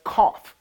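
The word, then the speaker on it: kaf (כ): What he said is, as we think of 2020 and 2021, well, 20 in Hebrew, again, is pronounced as kaf, and kaf is the palm of the hand.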